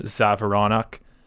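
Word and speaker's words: Džavoronok: And that's the Czech player, Donovan Zavoronok, with a silent D.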